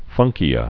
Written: funkia

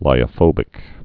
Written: lyophobic

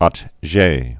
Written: Atget

